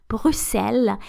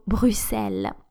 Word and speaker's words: Bruxelles: apparently the the, the, the actually the, the only one true and correct pronunciation is Bruxelles.